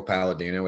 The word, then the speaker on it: Palladino: It's my name, Michael Palladino. It rhymes like that, you know?